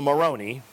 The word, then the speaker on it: Moroni: And then the angel Moroni, rhymes with macaroni, so it must be Italian, the angel Moroni, of course, he's not the only Italian angel.